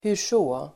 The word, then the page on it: hurså: [hur_s'å:]